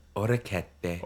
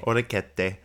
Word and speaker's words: Orecchiette: It was the orchetti. How do you say that? – Orecchiette. – Orecchiette.